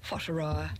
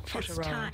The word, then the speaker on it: Whataroa: W-H-A-T-A-R-O-A. – Whatteroa. Yeah, Whatteroa.